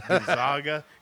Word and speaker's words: Gonzaga: What's the team that I kept on saying it wrong? Gorgonzola? – Yeah. – Gonzaga.